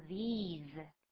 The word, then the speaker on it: these: 对，就是单数跟复数分不清楚，单数是念作 this，是短音 this，后面的 s 就是念作 s 的音 this，就是复数不一样，第一个母音不是 i，而是 e，而且是比较长的，那再来呢 s 念作 z，念作 z 的音，所以呢复数的要念作 these。